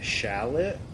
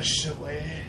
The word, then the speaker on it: chalet: How do you spell it? – It's like C-H-A-L-E-T. – Oh, the chalet. – Yeah, it's a chalet. Well, that is it. Well, yeah, chalet. L-L-O-T, right? – Yeah.